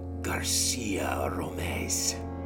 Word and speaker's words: Garcia Romez: It is Garcia Romez. – Is it pronounced scary like that, or? – Yes, that is an actual intonation.